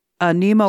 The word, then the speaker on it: Niamh: If you get on YouTube and just Google her name, Niamh O'Connor, you'll get, she's Irish. So Niamh is spelled N-I-A-M-H.